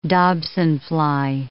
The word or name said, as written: dobsonfly